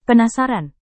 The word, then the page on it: penasaran: Indonesian